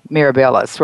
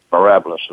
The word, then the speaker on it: Mirabilis: Now, at that time, there were a whole bunch of companies involved under the umbrella of Mirabilis, right? – Mirabilis is the way it's pronounced, but anyway.